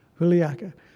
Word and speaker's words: Juliaca: It's Juliaca, Peru, in 2019.